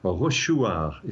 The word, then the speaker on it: Rochechouart: It's in a place called Rochechouart is probably the way the locals pronounce it, which is in central France.